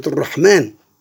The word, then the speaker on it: الرحمن: في المقابل نجد هذه الألف تنطق في حروف في كلمات ولا تكتب، مثل كلمة الرحمن، كلمة لكن.